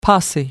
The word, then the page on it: posse: American English